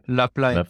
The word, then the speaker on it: La Plagne: La Plagne.